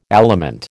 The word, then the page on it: aliment: American English